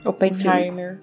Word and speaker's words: Oppenheimer: Como que se pronuncia? É Oppenheim? Acho que é, né? – Oppenheimer. – Isso.